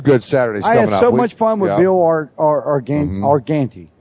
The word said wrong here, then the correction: Argente: Yeah. – Let me say that correctly. – Yeah. Argente.